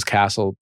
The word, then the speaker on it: Casel: My last name is Castle. It's spelled C-A-S-E-L.